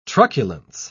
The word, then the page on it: truculence: British English